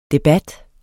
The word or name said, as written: debat